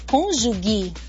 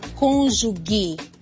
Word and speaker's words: cônjuge: Você diz meu cônjuge? Lembre-se, o G antes de E e I em português tem som de J. Portanto, nada de cônjuge. A pronúncia correta é cônjuge.